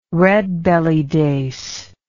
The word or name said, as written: redbelly dace